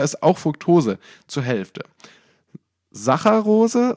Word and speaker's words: Saccharose: Ich weiß genau nicht, das tut mir wirklich leid, aber ich weiß nicht, wie Saccharose ausgesprochen wird. Ich glaube so. – Das ist schon richtig. – Saccharose.